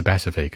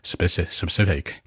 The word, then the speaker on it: specific: 注意啊，不是 specific，这个发音重音往前靠，specific。哎呀，大舌头了，抱歉。